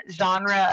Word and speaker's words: genre: So get rid of one genre of music, what would it be? Is that how you say it? Genre? – Yep, you're right.